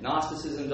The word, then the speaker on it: Gnosticism: It doesn't start with an N. Gnosticism doesn't start with an N. It starts with a G. It's a silent G.